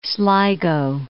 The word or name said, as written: Sligo